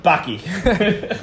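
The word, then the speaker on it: Paqui: Hey, we're Paki, pronounced Paki.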